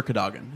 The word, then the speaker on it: Cadogan: Now, the last thing in my section is Sir Cadogan.